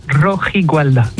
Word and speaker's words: Rojigualda: Rojigualda, c'est bien c'est ça, Rojigualda.